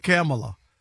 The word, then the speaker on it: Kamala: Rick Kamala. Camel, cigarette. – Why are you adding an extra syllable, both of y'all? Kamala.